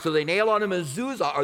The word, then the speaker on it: Mezuzah: Mezuzah.